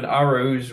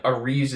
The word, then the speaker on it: Araúz: I forget how she pronounced it, but when Araúz was on second, she was like, oh, there's like, I forget how she said it, but it was like, oh, like Ariz is on second. And he was like, who? And she was like, oh, like Jonathan Ariz is on second. And he was like, it's Araúz.